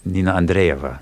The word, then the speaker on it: Nina Andreeva: Well, one of the most talked about pieces while you were in the Soviet Union was by Nina Andreeva. Did I pronounce that correctly? – Nina Andreeva.